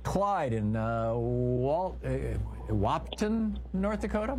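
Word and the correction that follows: Wahpeton: Am I saying it right, Clyde? – Yes, Wapiton, North Dakota.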